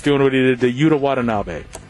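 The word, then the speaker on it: Yuta Watanabe: Utah Watanabe. – Oh, there you go. Boom. Utah Watanabe.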